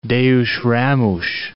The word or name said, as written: Deus Ramos